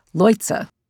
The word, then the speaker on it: Leutze: and I now know that his last name is pronounced Leutze.